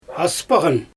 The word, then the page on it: aspaGan: Xyzyl